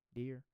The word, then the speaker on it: Dyer: By Wayne Dyer. That's how you say it. Dear.